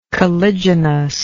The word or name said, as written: caliginously